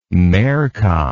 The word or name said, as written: merca